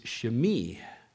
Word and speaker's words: Shimei: I don't know how you've heard it pronounced in the past, but in the Hebrew it is Shimei.